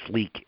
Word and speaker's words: Leake: used to be called Leake County Hospital, but when we acquired it, it's now called Baptist Leake, and then Leake is spelled L-E-A-K-E.